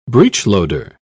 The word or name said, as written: breechloader